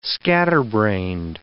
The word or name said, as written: scatterbrained